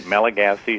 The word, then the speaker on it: Malagasy: And the church that you work with there, is it the Malagasy Lutheran Church? Did I say that right? – Yep, that's right. Malagasy is the name of the people from Madagascar.